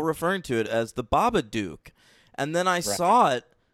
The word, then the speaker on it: Babadook: And I prefer the proper pronunciation, The Babadook, because it's supposed to rhyme with fucking book in that movie.